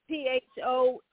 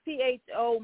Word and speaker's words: Pho: P H O. Yeah, it's P H O Midway, and P H O I'm told is pronounced as Pho, like F U H.